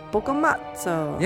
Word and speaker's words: Boccamazzo: Boccamazzo.